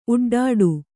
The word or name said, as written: ಉಡ್ಡಾಡು